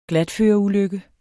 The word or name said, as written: glatføreulykke